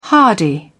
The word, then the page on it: hardy: British English